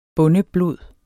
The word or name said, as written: bondeblod